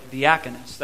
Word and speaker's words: diakonos: The Greek word for servant is pronounced or sounds like diakonos. That's how it's stated. You can hear the word deacon in that, can't you? Diakonos, deacon.